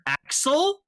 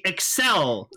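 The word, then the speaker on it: Accel: Because George says, because this pisses me off, people think it's pronounced Kamen Rider Axel, but it's clearly Excel. – Yeah. – Like the word accelerate. – Yes. – So it's not Axel, people. It's Kamen Rider Excel.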